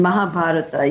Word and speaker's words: Mahabharata: And in particular, and correct me if I mispronounce this, you say you still hadn't discovered Mahabharata. Is that correct? – Yes. Absolutely perfect pronunciation. Mahabharata, yes.